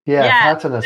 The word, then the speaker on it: Patana: Is it Patana? – Yeah. Patana School, Cindy.